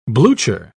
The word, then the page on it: blucher: British English